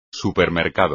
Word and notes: supermercado: espagnol

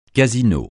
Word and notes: casino: francais

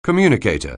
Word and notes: communicator: anglais

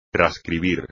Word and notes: trascribir: espagnol

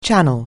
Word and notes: channel: anglais

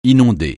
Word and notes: inonder: francais

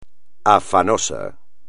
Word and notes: afanoso: espagnol

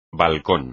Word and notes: balcón: espagnol

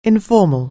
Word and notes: informal: anglais

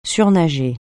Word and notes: surnager: French

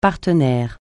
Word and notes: partenaire: francais

